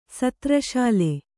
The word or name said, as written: ಸತ್ರಶಾಲೆ